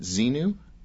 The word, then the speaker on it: Xenu: Speaking of what Ron Hubbard teaches, can you tell me about, is it Xenu? Did I pronounce it right? – Yeah.